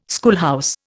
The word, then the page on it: schoolhouse: Indian